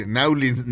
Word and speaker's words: New Orleans: le dicen New Orleans. O sea, se escribe N-A-W-E-U-L-N-I-S, Nowlins, Nowlins. O sea, porque así se oye.